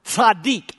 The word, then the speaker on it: tzaddik: It's a hard word to pronounce. It's a Hebrew word, tzaddik. I won't ask you to say it because you'd have to spit on the person in front of you.